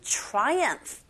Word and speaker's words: triumph: With the good in triumph 라고 해서 승리로 끝났습니다. 선의 승리로 끝났습니다. 이렇게 제가 해석을 해봤어요. Triumph PH가 F 발음이 나게 되죠.